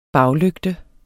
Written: baglygte

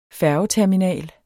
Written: færgeterminal

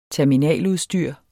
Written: terminaludstyr

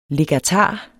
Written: legatar

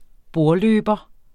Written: bordløber

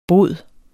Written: bod

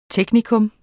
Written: teknikum

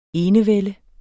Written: enevælde

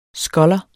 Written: sculler

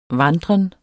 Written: vandren

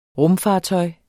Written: rumfartøj

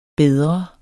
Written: bedre

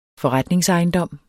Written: forretningsejendom

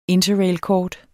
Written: INTERRAILKORT

